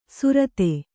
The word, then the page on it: ಸುರತೆ: surate